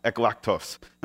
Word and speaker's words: eklektos: Okay, because you all read Greek, and if you don't, this is how it's pronounced, eklektos.